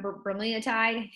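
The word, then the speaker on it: bremelanotide: So PT-141 is, can't even pronounce, bromelotide, is how you pronounce it.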